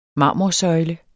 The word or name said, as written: marmorsøjle